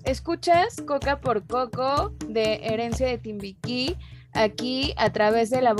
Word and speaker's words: Herencia de Timbiquí: herencia de Timbiquí nos sí se pronuncia así verdad sí exacto